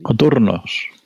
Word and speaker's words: coturnos: Eh, si bien es cierto que los actores de teatro griego utilizaban una especie de tacones, conocidos como cozorni o cotorni, no sé cómo se pronuncia. – Coturnos, coturnos.